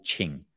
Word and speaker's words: Qing: In Taoism, it's in Chinese, it's called Qing. So in Pinyin Romanization, you'd spell that Q-I-N-G.